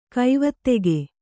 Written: ಗವೇಧುಕೆ

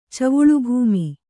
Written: ಚವುಳುಭೂಮಿ

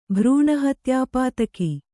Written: ಭ್ರೂಣಹತ್ಯಾಪಾತಕ

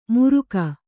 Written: ಮುರುಕ

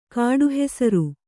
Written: ಕಾಡುಹೆಸರು

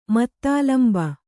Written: ಮತ್ತಾಲಂಬ

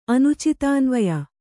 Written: ಅನುಚಿತಾನ್ವಯ

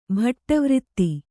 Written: ಭಟ್ಟವ್ರಿತ್ತಿ

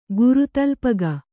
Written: ಗುರುತಲ್ಪಗ

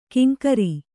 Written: ಕಿಂಕರಿ

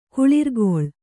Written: ಕುಳಿರ್ಕೊಳ್

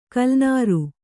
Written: ಕಲ್ಲನಾರು

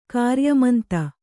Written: ಕಾರ್ಯಮಂತ

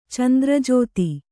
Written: ಚಂದ್ರಜೋತಿ

